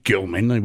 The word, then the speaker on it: Gilman: I mean, his name's not, his name's Gilman. We got to pronounce it appropriately.